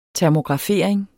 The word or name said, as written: termografering